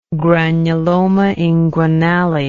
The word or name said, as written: granulomainguinale